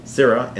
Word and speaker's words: Scirra: That's pronounced Cira and spelled S-C-I-R-R-A.